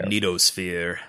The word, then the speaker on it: magnetosphere: our magnetic sphere. Our magnetic sphere. I think I'm still kind of saying that one syllable off, but I don't care. – Magnetosphere. – Yeah, magnetosphere. That's it.